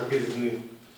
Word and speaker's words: Quesnay: Allora, il caposcuola di questa di fisiocrazia si chiama Quesnay, scritto Quesnay con la Y, e come francese si pronuncia Quesnay.